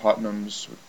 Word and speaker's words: Putnam: You know, you can see he has issues with the Putnams, how you say it? – I think it's Putnam.